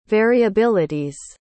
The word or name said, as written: variabilities